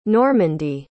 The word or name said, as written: Normandy